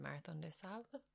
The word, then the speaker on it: Marathon des Sables: No, and I know you were specifically training for MDS, or I'm going to kill the pronunciation, Marathon des Sables.